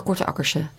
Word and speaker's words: Korte Akkerse: Maar je bent echt een Korte Akkerse. – Ja. – Zeg ik dat goed zo? – Ja.